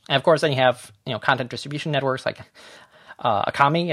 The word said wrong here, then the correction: Akamai: as I don't even know if you say that correctly, but it's, in general... – I think it's Akamai. – Is Akamai, thank you. I thought I was saying that wrong.